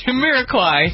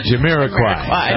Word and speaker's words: Jamiroquai: Jamiroquai. – Jamiroquai.